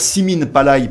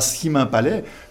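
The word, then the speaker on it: Simin Palay: Voilà, donc moi, je le dis encore Simine Palaï, et pas Simin Palais, puisque son honneur, c'est d'avoir mis à l'honneur la langue locale, on va quand même le prononcer à sa façon.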